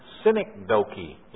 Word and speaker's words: synecdoche: And then, synecdoche. Yes, that's how you pronounce it.